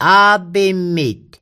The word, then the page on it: a'pimit: Mi'gmaq